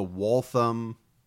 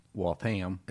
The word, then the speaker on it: Waltham: We've got a great episode this week about the Waltham. I keep saying it wrong. – It's Waltham. – It's Waltham. Triple murders.